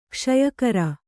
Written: ಕ್ಷಯಕರ

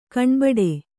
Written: ಕಣ್ಬಿಡು

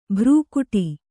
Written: ಭ್ರುಕುಟಿ

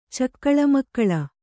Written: ಚಕ್ಕಳಮಕ್ಕಳ

